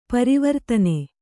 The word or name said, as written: ಪರವರ್ತನ